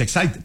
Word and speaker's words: Exide: Se escribe Exide, se pronuncia Excite.